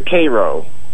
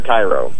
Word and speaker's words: Cairo: Yeah, it's Victor Cairo. – Victor Cairo, a.k.a. – No, actually, it's Victor Cairo. – Cairo? – Pronounced like Egypt.